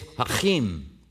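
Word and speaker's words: Jachin: I was asking my wife how you say it, because it looks like Joaquin, but it's not. It's Hebrew, and the CH in the Hebrew is the ch sound. So it's Hachim.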